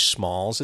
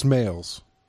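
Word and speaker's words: Smails: So a young Danny Noonan seeking money for college pursues benefactor, Judge Smalls. Is it Smalls or Smales? – It's pronounced Smales, but okay. Spelled Smalls.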